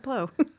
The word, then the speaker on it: Bleau: It's Bleau. Is it Blow? – Wait. Sounds like Blow. – It sounds like Blow.